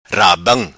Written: rabam

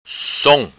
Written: Som

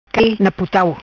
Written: Cai Na Putau

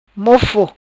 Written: Mofo

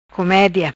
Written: Comedia

